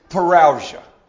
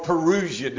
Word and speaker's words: parousia: Now, I've told you in the past, the technical word that we use to discuss this is parousia, or parousia, depending on where you're from, how you pronounce that. That diphthong in Greek.